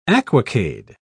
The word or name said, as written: aquacade